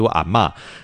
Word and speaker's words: 阿嬷: 叫月下煮茶，其实这个阿嬷呀，很多的那个发音，我们查的是阿谋，但是潮汕那边读阿嬷。